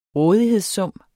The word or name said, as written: rådighedssum